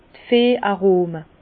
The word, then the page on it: d' Veh Arome: Bas Rhin; Herrlisheim